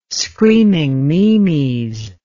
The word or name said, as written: screaming meemies